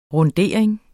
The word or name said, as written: RUNDERING